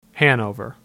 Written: Hanover

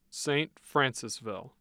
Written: St. Francisville

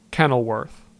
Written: Kenilworth

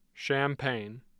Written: champaign

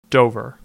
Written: Dover

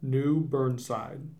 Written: New Burnside

